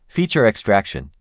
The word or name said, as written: Feature extraction